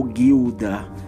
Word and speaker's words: Gilda: Vamos falar aqui sobre o filme Gilda, ou Guilda, que é a pronúncia certa,